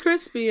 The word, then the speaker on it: Chrispy: And my name is Crispy, and that's spelled C-H-R-I-S-P-Y.